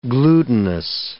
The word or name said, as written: glutinous